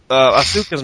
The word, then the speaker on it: Asuka: I saw her match, Asuka's match. Oh, God. Okay. – It is pronounced Asuka, by the way. Or at least the U is very, very short.